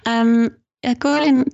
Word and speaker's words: Guilin: Because I'm pronouncing it incorrectly when I say Guilin. – No, it's correct. Guilin does offer that in that the roads are busy because it's well developed.